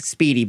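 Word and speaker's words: spiedini: But they call it speedy like I'm quick, speedy, but it's spelled I-E. – Okay. – But they pronounce it speedy.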